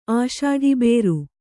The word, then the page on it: ಆಷಾಢಿಬೇರು: Kannada